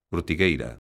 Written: ortigueira

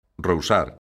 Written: rousar

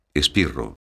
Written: espirro

